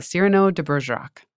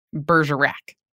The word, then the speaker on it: Cyrano de Bergerac: Just call it Cyrano de Bergerac. soft A, not Bergerac. And he's like, let your American version be what it is.